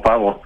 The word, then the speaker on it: Paavo: Sí, bueno, mi nombre es de Finlandia, pero yo crecí en Los Ángeles, California. Entonces yo digo Paavo como Paavo. – Okay. – Pero la pronunciación finlandesa debe ser diferente.